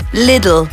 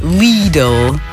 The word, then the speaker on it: Lidl: Lidl. Nuh-uh. Lidl, according to the experts.